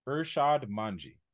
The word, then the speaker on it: Irshad Manji: Oh, Don't Label Me by I always say her name is Ihsan Minhaj. Miss, don't mind me just typing. It is Irshad Manji. Irshad Manji.